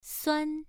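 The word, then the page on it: suan: tone 1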